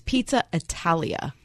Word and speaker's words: Pizza Italia: I just realized I said it wrong. – Huh. – It's Pizza Italia, not Italiana.